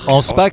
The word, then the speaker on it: Anspar: On prononce avec le comme nous, on roulait le R, Anspar, on faisait un truc très germanique en fait. À tort, sans doute.